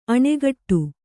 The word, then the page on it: ಅಣೆಕಟ್ಟೆ: aṇe kaṭṭe